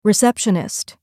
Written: receptionist

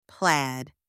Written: plaid

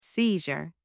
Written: seizure

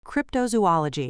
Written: cryptozoology

